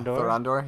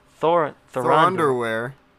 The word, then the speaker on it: Thorondor: Who shows up but Thorondor? Did I say that right? – Yeah, Thorondor. Yeah. – Thorondor.